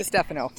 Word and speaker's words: DiStefano: DiStefano.